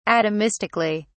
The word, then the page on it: atomistically: British English